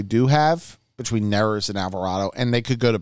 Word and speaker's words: Alvarado: Is that how I pronounce it? – Yeah, Alvarado. I would say,